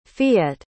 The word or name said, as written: fiete